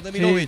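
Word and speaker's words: Demirović: Demirovic, Demirovic, ¿está bien pronunciado? Sí, Demirovic.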